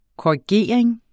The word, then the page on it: korrigering: kɒiˈgeˀɐ̯eŋ; koɐ̯i-